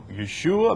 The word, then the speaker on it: Yeshua: He's Yeshua. You use the Hebrew pronunciation. Now, I don't have a problem with, you know, Yeshua.